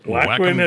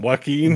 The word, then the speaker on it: Joaquin: Not many characters in movies and media are named Bo except for stupid Joaquin Phoenix and his It's all going to change, bro. It's all going to change. – Whackwin, how do you say him? Joaquin? – Joaquin is his name, but I like Whackum.